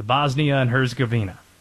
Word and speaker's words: Bosnia and Herzegovina: And yes, I'm proud that I'm pronouncing the name of the country right. Yes, I had to have it phonetically spelled out in front of me in order to nail it.